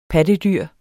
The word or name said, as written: PATTEDYRENE